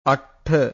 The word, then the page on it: attha: Pali